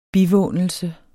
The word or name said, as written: bivånelse